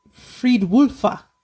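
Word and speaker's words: Fridwulfa: I can spell it for you, but I can't pronounce it. Oh, it's Friedwulfa. – There you go.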